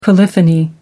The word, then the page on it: polyphony: American English